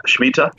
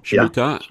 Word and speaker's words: Shemitah: is it pronounced Shemitah? – Yep, Shemitah. – Shemitah, yeah.